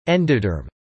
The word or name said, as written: endoderm